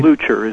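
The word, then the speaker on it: Leuchter: Am I pronouncing his name right? – Luchter is the way Luchter. He pronounced it, yeah.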